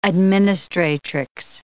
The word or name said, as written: administratrix